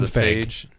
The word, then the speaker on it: Feige: Speaking of Captain Marvel, Kevin Feige, is that how you say his name? – Kevin Feige. Or whatever? – Feige. – Whatever. Feige. Okay.